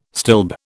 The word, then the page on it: stilb: British English